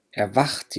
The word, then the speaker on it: erwacht: erwacht يعني هون تستيقظ، الساء والهاء تلفظ مثل حرف الخاء باللغة العربية.